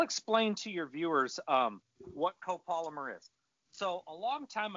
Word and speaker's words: copolymer: I fished it a lot last year, but the copy polymer, am I saying it right? – Copolymer. – Copolymer line.